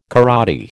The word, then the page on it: karate: American English